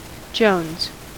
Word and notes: jones: American English

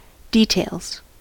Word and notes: details: American English

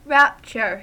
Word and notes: rapture: American English

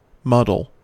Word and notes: muddle: American English

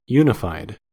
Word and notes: unified: American English

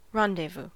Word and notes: rendezvous: American English

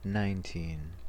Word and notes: nineteen: American English; stressed

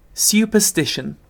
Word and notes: superstition: British English

